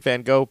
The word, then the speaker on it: Van Gogh: But he was on some podcast, and the guy's like, you know, it's like that Vincent van Gogh painting, and Simmons stops and goes, excuse me, it's Van Gogh. That's how it's pronounced. Van Gogh.